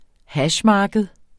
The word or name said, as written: hashmarked